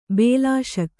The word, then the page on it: ಬಿಲ್ಲೆಸುಗೆ: billesuge